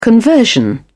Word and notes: conversion: British English

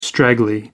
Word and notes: straggly: American English

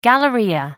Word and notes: galleria: American English

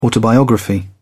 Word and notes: autobiography: British English

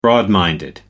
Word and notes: broad-minded: American English

